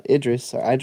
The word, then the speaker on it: Idris: But I am excited for Idris.